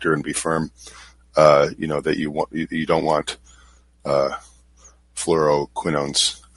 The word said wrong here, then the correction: Fluoroquinolones: I think I said that wrong. Fluoroquinolones.